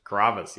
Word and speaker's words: Carrabba's: Carrabba's? – Carrabba's, yeah.